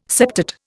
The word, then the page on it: septate: Indian